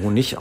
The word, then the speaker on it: Honig: Ich glaube, es wird tatsächlich mit CH hinten Honig ausgesprochen.